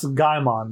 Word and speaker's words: Gaimon: It's it's pronounced Gaimon, the keeper of the ah, island. Ah, Gaimon.